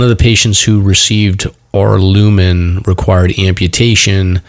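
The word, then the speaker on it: Aurlumyn: It's A-U-R-L-U-M-Y-N. – Arlumin. Arlumin. – Arlumin, yes.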